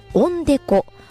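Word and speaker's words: 鬼太鼓: なんと 1 位は、岩佐美咲さんの佐渡のオンデコ、鬼に太鼓と書いてオンデコと読むそうです。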